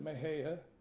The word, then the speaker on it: Mexia: in the little town of Mahia, Texas. It's spelled M-E-X-I-A. They pronounce it Mahia,